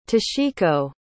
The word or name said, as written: toshiko